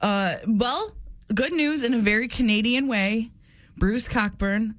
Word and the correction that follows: Cockburn: the writer of Coburn. – Oh, sorry. – It's okay. – It's spelled funny.